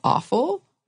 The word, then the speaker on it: offal: But we're talking about sweetbreads as in a type of offal. That's how you pronounce it, right? – Yeah. – Yeah. – Offal.